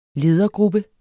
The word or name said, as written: LEDERGRUPPE